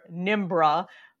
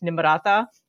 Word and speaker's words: Nimrata: Clearly painting her as, quote unquote, foreign by calling her Nimbra. And by the way, the comedy bit, I know it's not her fault, but it's actually pronounced Nimrata. So, I mean, I don't expect most people to pronounce Nikki Haley's birth name correctly.